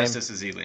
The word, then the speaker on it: Festus Ezeli: Festus Ezeli.